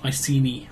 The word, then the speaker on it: Iceni: So the Iceni, is that how you say it? – Iceni.